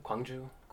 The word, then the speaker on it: Gwangju: Gwangju.